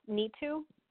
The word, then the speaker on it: Nitu: Hi there, my first name is Neetu, and I'm calling from Texas. – And tell me the first name again. – Sure, it's Neetu. – Like N-E-E-T-U? – Yeah, that's how it's pronounced. Yeah, the spelling is N-I-T-U, but it's exactly how it's pronounced.